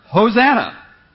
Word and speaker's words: Hosanna: And if I pronounce it in Hebrew, I pronounce it Hosanna.